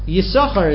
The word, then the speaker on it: Yisachar: Yisachar is pronounced without the second sin.